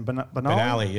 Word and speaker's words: Benali: But also, another, not to get too much on a downer here, but since our last episode, Frankie Benali, how do I say his name? – Benali, yeah.